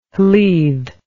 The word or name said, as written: Lludd